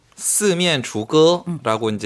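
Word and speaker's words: 스면주거라고 (四面楚歌): 사면초가 스면주거라고 이제 중국어로 발음이 되는데요.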